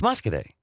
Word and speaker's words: Muscoda: What was the town that we thought was Muscoday? – It's Muscoday. – Muscoday. What did we? – It's spelled Muscoda.